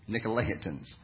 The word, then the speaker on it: Nicolaitans: There they called it the teachings of Balaam, or the teachings of the Nicolaitans. I said it right, Nicolaitans.